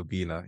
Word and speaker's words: Obinna: Completely fine, Belinda, Obinna, yeah.